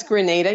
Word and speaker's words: Grenada: I'm sorry, that's Grenada. You know, Grenada and Granada, everyone gets it mixed up.